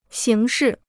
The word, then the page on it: 刑事: xíng shì